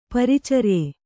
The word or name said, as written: ಪ್ರಾಚಾರ್ಯ